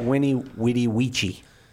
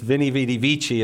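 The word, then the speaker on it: veni vidi vici: That's the classical pronunciation of Winnie. – Is that really how you're supposed to say that? Winnie Witty Weechy? – Classical Latin, yes, right? Winnie Witty Weechy. Okay. But it's Vini Vidi Vici in church Latin.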